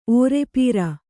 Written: ಓರೆಪೋರೆ

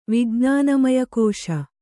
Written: ವಿಜ್ಞಾನಮಯಕೋಶ

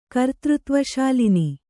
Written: ಕರ್ತೃತ್ವಶಾಲಿನಿ

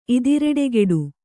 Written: ಇದಿರೆಡೆಗೆಡು

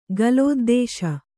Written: ಗಲೋದ್ದೇಶ

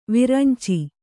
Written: ವೈರಿಂಚಿ

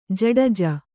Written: ಜಡ್ಜಿ

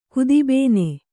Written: ಕುದಿಬೇನೆ

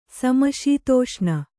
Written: ಸಮಶೀತೋಷ್ಣ

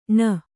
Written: ಣ

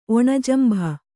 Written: ಒಣಜಂಭ

